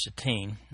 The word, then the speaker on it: Shittim: The actual Hebrew word there is pronounced Shittim, accent on the second syllable.